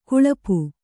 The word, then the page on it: ಗಳಪು: gaḷapu